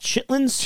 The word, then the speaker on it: chitterlings: Chitterlings. – Yep. Chitterlings. – I guess it's actually pronounced chitlins. – Chitlins. – But it's spelled chitterlings.